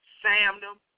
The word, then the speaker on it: Samnem: But how I've been saying it is I say Samnem. That's how I pronounce it. S-A-M-N-E-M. Again, S-A-M-N-E-M. Samnem. That's how I say it.